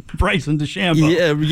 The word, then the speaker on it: DeChambeau: Your guy, Rochambeau, had a really nice weekend. – You mean Bryson DeChambeau? – Yeah, Tyson.